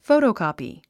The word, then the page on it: photocopy: fóutoukɔ̀pi; フォゥトゥコピィ